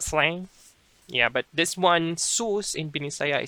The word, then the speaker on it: sus: Now the word is sus. Sus. It's spelled as S-U-S. Sus. Almost like sus in American English.